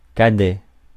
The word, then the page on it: cadet: /ka.dɛ/